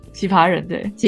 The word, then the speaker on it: 奇葩人: 同时也呼吁，就是哦，阿啊，丑一丑一，他自己有一个 podcast 叫《奇葩人》吗？我发音有发对吗？ – 奇葩人对，奇葩人对，大家也就是可以踊跃去留言，就是让我们的 podcast 有多一点 feedback。